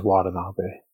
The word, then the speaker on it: Watanabe: I pronounce it Yuta Watanabe, but I probably didn't pronounce it right. How did you say it? – I think you're right. I think it's Watanabe.